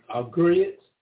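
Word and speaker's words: griot: He's what they call, how you pronounce that, a Agriot, a historian, African name is called historian, G-R-I-O-T, I think that's the spelling of it.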